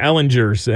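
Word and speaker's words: Ehlinger: Well, all week long, I heard a whole bunch of Ellingers. And I'm like, it's Ellinger. Give me the hard G, man.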